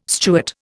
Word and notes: Stuart: Indian

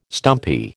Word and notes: stumpy: American English

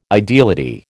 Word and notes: ideality: American English

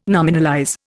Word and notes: nominalize: Indian

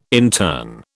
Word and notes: intern: British English

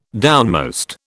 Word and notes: downmost: British English

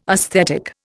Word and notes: aesthetic: Indian

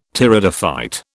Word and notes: Pteridophyte: British English

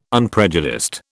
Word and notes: unprejudiced: British English

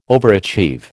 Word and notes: overachieve: American English

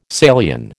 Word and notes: Salian: American English

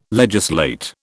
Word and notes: legislate: British English

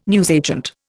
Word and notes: newsagent: Indian

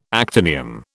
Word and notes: actinium: British English

